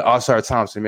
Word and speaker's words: Ausar Thompson: How do you say his name? Ausar Thomas. What's his name, man? – Thompson. Yeah. – Yeah, Ausar Thompson, man.